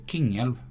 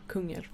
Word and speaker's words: Kungälv: Man, ni som inte bor här tror att man säger Kungälv. Men man, men man ska uttala Kungälv som ett, ett tyskt U. Med två prickar. Så det blir Kingälv.